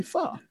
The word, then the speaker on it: pho: And the one guy that really knows how to say it says, guys, I know you guys are Asian too, but it's actually pho, you know, not pho.